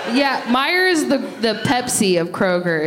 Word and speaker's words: Meijer: What's the name of that place that they're from? – Meyer. – That's how you pronounce it? – Yeah.